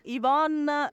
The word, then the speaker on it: Yvonne: Sì, Yvonne Lanzuisi. Yvonne si scrive con la Y, si scrive Yvonne contattatemi su Instagram tranquillamente, e rispondo tranquillamente a tutti quanti.